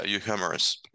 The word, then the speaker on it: euhemerism: And I think our next discussion is going to be on euhemerism. Is that how you pronounce it? – Yeah, you humorous. Yes.